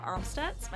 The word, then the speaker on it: Amstutz: And you can find me on all social media platforms at Sandra Armstutz.